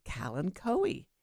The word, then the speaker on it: kalanchoe: And the dirty word of the day is kalanchoe, K-A-L-A-N-C-H-O-E. Now, Americans pronounce it kalanchoe, while the British say kalancho.